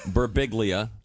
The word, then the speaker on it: Birbiglia: Now, Birbiglia, when I looked at it at first, I thought it might be the silent G. – Yeah, no, Birbiglia. I mean, I get that a lot. Sometimes people come up to me, they'll be like, "In Italy, it's pronounced Birbiglia."